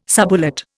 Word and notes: subulate: Indian

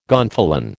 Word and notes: gonfalon: American English

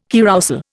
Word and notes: carrousel: Indian

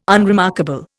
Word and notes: unremarkable: Indian

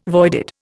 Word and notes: voided: Indian

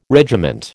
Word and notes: regiment: American English

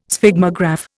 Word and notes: Sphygmograph: Indian

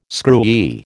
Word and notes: screwy: American English

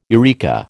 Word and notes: eureka: American English

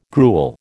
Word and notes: gruel: American English

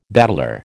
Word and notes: bateleur: American English

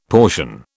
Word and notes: portion: British English

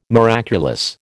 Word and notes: miraculous: American English

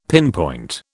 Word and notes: pinpoint: British English

